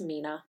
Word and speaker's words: Mina: I did look it up in the break. It's Mina. I was very afraid to keep saying it wrong, and it's Mina, but I did like saying Mina, Mina, Mina, Mina.